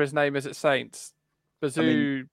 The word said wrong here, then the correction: Bazunu: It's Bazunu, I think it is pronounced. – Bazunu.